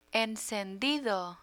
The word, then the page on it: encendido: Spanish